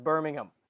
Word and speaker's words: Birmingham: but makes fun of us because she says the H is silent. It's just Birmingham.